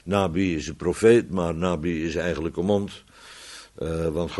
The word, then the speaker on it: Nabi: Dat komt omdat de profeet, dat weet iedereen, hè, profeet, dat is Nabi. Dat is Nabi, of Nebi, hoe ze het maar uitspreken, Nabi.